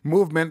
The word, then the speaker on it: MVMT: Movement, that's spelled MVMT, it's grown into one of the fastest growing watch brands, and they're shipping to over 160 countries across the globe.